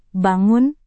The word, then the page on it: bangun: indonesian